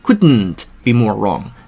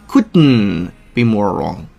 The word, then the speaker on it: couldn't be more wrong: 第二点，当 couldn't 结尾那个 t 遇到了 be 动词的那个 be 那个音之后，那个 couldn't 的 t 就失去爆破了，所以不用读成 couldn't be more wrong，你就可以直接读成 couldn't be more wrong，那个 t 音消失了，直白口型，不送气，留出反应时间，couldn't be more wrong，couldn't be more wrong。